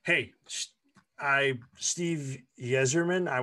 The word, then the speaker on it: Yzerman: I think it's Zerman. I think the Y is silent.